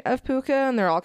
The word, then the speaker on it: Pooka: So the meaning of Pooka, which I have pronounced correctly, is Pooka.